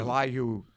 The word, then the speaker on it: Elihu: Elihu, yeah.